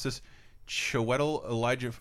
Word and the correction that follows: Ejiofor: How do you say it? – Ejiofor. – Chiwetel Ejiofor.